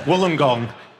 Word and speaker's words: Wollongong: Is that how it's pronounced? Is this like a... – From the reaction, I'm not sure that's how you do pronounce it. – Wollongong. You can. I am Australian.